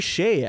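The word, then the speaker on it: Skjei: Brady Sketch. – Brady Shea, actually, is how you say it. Brady Shea. – Wow, that's a Shea.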